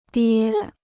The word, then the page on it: 爹: diē